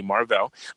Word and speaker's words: Mar-Vell: Secondly, Captain Marvel, how, you know, everybody pronounces it, but it's really Mar-Vell.